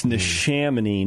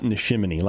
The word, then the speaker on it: Neshaminy: It's Neshaminy, not Neshaminy, like ShamWow.